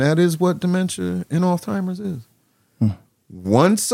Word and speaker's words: Alzheimer's: It ain't Alzheimer's, it's Alzheimer's. You gotta use the German part, Alzheimer's, that's what it is.